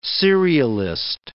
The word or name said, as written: serialist